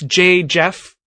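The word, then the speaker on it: JJeff: It's J Jeff. I pronounce it Jeff or double J E double F.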